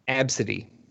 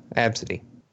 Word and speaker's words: ABCDE: Yes, like, I, they I, they I, they wanted to pronounce it Absidy.